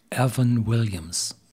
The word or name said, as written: Evan Williams